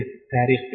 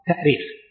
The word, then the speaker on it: تأريخ: أيهما أصح في الكتابة والنطق في كلمة التاريخ بالهمزة والتأثير؟ طبعا من حيث الأصل الاشتقاقي تأريخ، التأريخ بالهمز لأنها أرخ يؤرخ، الهمز أصلي فيه، أرخ يؤرخ تأريخا. وأما التاريخ فهو تسهيل،